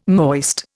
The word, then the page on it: moist: Indian